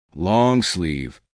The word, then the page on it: long sleeve: American English